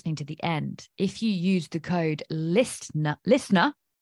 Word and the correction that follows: listener: I pronounced the T for some reason, listener, there will be a discount for you on Learn to Predict.